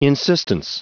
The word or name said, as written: insistence